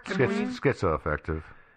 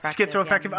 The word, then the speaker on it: schizoaffective: It's that Blair was having some kind of mental break, it's a socioaffective disorder. – Schizoaffective. – Is it schizo? – Schizoaffective.